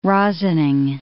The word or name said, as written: rosining